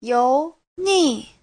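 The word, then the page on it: youni: you2ni4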